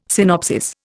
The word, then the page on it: synopsis: Indian